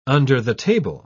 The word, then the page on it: under-the-table: British English